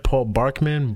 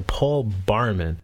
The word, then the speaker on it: Paul Barman: Alright, this Paul Barman, am I saying it right? Paul Barkman? Barman? Okay, Paul Barman. – Paul Barman. At least I know how to say his name. Everybody else seems to be fucking up his name lately.